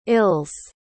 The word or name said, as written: ills